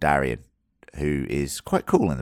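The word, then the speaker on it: Daeron: Darren. It's Darian. – Darren. – Darian. Okay, Darian is better. The way I should pronounce it.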